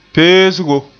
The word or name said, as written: peesgo